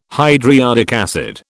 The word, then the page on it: hydriodic acid: British English